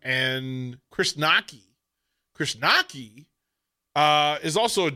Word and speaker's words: Knoche: And the way it's spelled, K-N-O-C-H-E, Nockey.